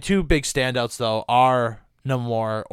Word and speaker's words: Namor: But I'm gonna say Namor because that's how Namor pronounces it, just like Shang-Chi.